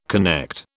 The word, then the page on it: connect: British English